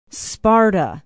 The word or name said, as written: sparta